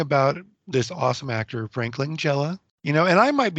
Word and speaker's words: Langella: Frank LaGaglia that was the. – Frank Langella. – Langella? – Yeah.